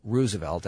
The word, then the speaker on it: Roosevelt: He preferred Roosevelt as the pronunciation.